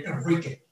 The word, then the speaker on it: Enrique: I live in the United States and I like when people pronounce my name Enrique, like it is.